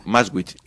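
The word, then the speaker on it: Maschwitz: ¿cómo se dice? ¿Maswich o cómo se Maswich. – Dice? Maswich. En Ingeniero Maswich,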